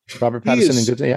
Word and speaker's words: Robert Pattinson: And Good Time was what – so a lot of people are down on Robert Pattinson. Am I saying his name properly? – Yeah, Robert Pattinson.